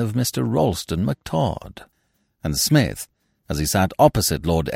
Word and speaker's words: Psmith: And now, leave it to Smith. The P is Silent, Part 4 of 10, by P.G.